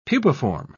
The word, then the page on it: pupiform: British English